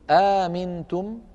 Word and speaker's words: أأمنتم: نأتي إلى الموضع الأول، أأمنتم، همزتان من كلمة واحدة لورش رحمه الله، فيها كما شرحنا ذلك في دروس سابقة مذهبان أو وجهان: الوجه الأول أن يبدل الهمزة الثانية ألفا فيصير النطق آمنتم،